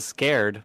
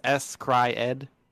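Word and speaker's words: s-CRY-ed: I know how to pronounce this one. It says Scared, but it's S Cry Ed.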